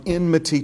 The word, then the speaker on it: enmity: And remember, we said that word enmity. It rhymes with enemy. It has to do with enemy, but it's not the same. Enemy and enmity are not the same word. For those of you listening, can't see the word on the screen. It's spelled E-N-M-I-T-Y, enmity. Enmity.